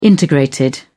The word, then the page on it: integrated: British English